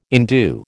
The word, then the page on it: endue: American English